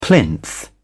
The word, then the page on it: plinth: British English